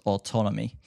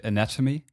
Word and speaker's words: ATNMY: It's by TZ and a EP called Wonder, which came out on his new label, ATNMY, pronounced Autonomy. And you know, the first time I saw it, I read Anatomy, and now I know it's Autonomy